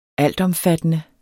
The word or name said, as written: altomfattende